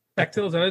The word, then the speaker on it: Bechdel: Bechdel, is that how it's pronounced? Okay. – Yeah, that's it.